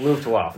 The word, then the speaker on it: Luftwaffe: Luftwaffe.